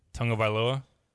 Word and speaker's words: Tagovailoa: How do you say it again? – Tagovailoa. – Tagovailoa. No, Tagovailoa. – Tagovailoa. There. Like I'm adding like Frank Viola at the end, the former Twins pitcher.